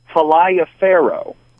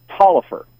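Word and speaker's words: Taliaferro: For example, I live in Wilkes County, and right next to us is a county that, if you look at it, it's spelled Falaya Faro. – Okay. – But it's actually pronounced Taillefer.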